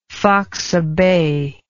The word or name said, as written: faxa bay